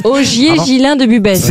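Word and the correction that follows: Busbecq: Augier Gilin de Busbeck, pardon, j'ai écorché son nom.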